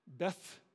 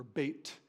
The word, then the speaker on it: Beit: Beth, and it's pronounced Bet, or Beit, Betel.